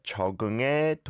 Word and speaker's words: 적응의: Going straight on to 적은 게 동물이야 can be awkward, but with that e syllable, the more you pronounce it as air, I think the easier it is to then slide on over to the next word. 적응의 동물이야, 적응의 동물이야, and then slowly speed it up.